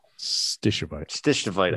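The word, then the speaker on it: stishovite: But he was asking, did they find coesite or schistavite? – Stishavite. – I never pronounced that right.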